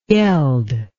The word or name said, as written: yeld